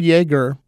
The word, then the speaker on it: Jaeger: The word Jaeger, as you can hear by the way I say it, is pronounced with a Y at the beginning, but it is spelled J-A-E-G-E-R.